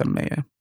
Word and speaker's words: Amaeya: It's the AE, right? Like the encyclopedia. – Ah. – Yeah. I mean, I did it because it was more a differentiating thing than anything else. But from a pronunciation point of view, it's Amaya.